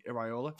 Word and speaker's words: Iraola: Iraola at Bournemouth.